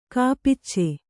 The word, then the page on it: ಕಪಿಚ: kapica